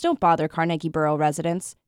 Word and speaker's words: Carnegie: So if you think of a word like carnival, Carnegie, carnival, or character with three syllables, where the default way to pronounce a word with three syllables, with certain exceptions, is to put the stress on the first syllable.